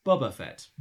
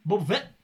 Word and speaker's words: Boba Fett: And it winds me up to an unreasonable degree that the voiceover pronounces Boba Fett as Bubba Fett.